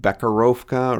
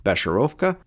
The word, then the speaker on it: Becherovka: Other than the difficulty in pronouncing Becherovka, I want to call it Bekarovka or Bekarovka, something. I've been training myself to have a proper pronunciation. I believe it to be Besherovka based on the YouTube videos I've watched, but I like it.